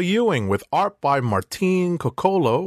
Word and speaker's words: Coccolo: So it is Martin Coccolo. So the stress is on the first syllable.